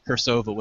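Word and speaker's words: Cirsova: I was actually a supporter of Kersova magazine on Kickstarter. Did I just say that wrong or right? I can't even remember. I'm so tired right now. – Kersova with a hard C.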